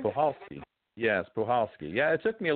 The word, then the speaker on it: Puchalski: It's not, because I was pronouncing it Pukalski to Pulhowski. I wasn't sure, but the C is silent, and the correct pronunciation is Pulhowski.